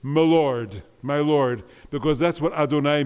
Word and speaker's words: Adonai: And now the third name for God in Hebrew, the one we pronounce, the euphemism, instead of saying, we say Adonai.